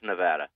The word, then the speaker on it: Nevada: He says I say Nevada. And it's Nevada, and I got a couple of letters saying it's Nevada.